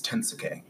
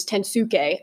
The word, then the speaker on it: Tensuke: I got into an argument with my tattooist about how to properly pronounce Tensuke. – I think it's Tensuke. – Yeah. That's how, you know, they say it with a Japanese accent.